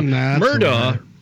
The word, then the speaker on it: Murdaugh: Now, Murdaugh, which kind of rhymes with radio law talk in a weird way, is...